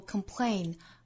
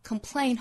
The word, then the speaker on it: complain: complain Complain 后面这个音节注意是一个 ain 的发音，里面包含了一个双元音的 a，所以会有一点不好发，不要发成 complain，应该是 complain。